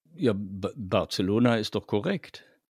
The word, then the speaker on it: Barcelona: Barcelona ist laut Aussprache Duden des Deutschen die korrekte deutsche Aussprache dieser Stadt. Man kann auch natürlich auf Spanisch Barcelona sagen mit dem stimmlosen TH, aber auf Deutsch kann man Barcelona sagen.